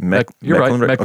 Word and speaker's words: Mecklenburg: So Linwood and James Briley were the ringleaders in a 6-inmate escape from Virginia's death row at Mecklenburg. I don't know if I'm saying that right. – Mecklenburg. – Okay.